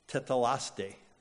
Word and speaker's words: tetelestai: In the original Greek, the term is this word, tetelaste. Tetelaste. It is spelled this way if you want to write it down. T-E-T-E-L-E-S-T-A-I. Tetelaste, which literally translated the term, it means it is finished.